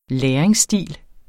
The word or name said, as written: læringsstil